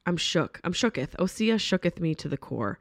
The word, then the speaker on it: Osea: I've been saying it right. Everyone said you're saying it wrong. No, I'm saying it right. Osea.